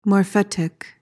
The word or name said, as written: morphetic